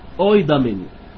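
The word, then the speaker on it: اويدامين (οἴδαμεν): النص بيقول يا جماعه، اويدامين. اويدامين يعني نعلم. اويدامين. ماشي يا فيصل؟ اوي دامين. دامين حرف الاوميكرون وحرف اليوتا يتنطقوا اوي مع بعض.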